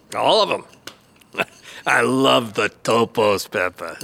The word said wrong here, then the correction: tapas: No, you say tapas. Tapas. No.